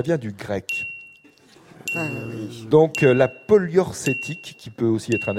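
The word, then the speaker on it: poliorcétique: polyorcétique avait eu une grande importance architecturale.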